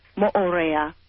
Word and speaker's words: Moorea: Is that the way it is pronounced? – Moorea. – Moorea. I'm sorry about that.